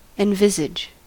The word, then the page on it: envisage: American English